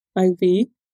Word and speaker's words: Iv: And so it kind of started out with IV. You can pronounce it any way you like.